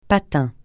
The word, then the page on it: patin: francais